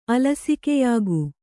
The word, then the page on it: ಅಲಸಿಕೆಯಾಗು: kannada